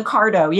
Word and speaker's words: Cardo: I think you said the Cardo. I've never heard of that. – Yeah. – Am I saying that correctly? – Yeah. The Cardo. Yeah. C-A-R-D-O.